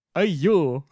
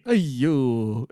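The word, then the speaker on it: Aiy-yoh: Spell it, it's A-I-Y dash Y-O-H, but you elongate. Ayo.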